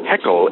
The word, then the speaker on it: Haeckel: He read a German philosopher and biologist named Ernst Haeckel, I believe that's how it's pronounced, H-A-E-C-K-E-L,